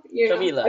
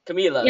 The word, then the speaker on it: Camila: So it's Camila, just like Camila Cabello. – Camila. Okay. Camila. – Yeah. – Did I say it right? – Perfect. You got it.